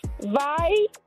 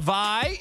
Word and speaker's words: vai: You have to say vai. Can you repeat? Say vai. – Okay. Vai, yep. – Chamas. – Chamas. – Vai. – Vai.